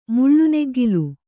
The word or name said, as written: ಮುಳ್ಳುನೆಗ್ಗಿಲು